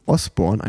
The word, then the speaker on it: Osborne: Da muss man mal schauen, ob Jason, Esbo äh Osborne, Jason Osborne, einmal richtig ausgesprochen, seinen WM-Titel verteidigen kann.